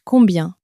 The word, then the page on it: combien: French